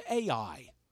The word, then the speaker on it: Ai: Because right after they got done with Jericho, there was a little tiny town, like Mechanicsburg size, of Ai. How do you spell that? A-I. That's it. So if you're writing, make this real easy on you. A-I. Little town called Ai.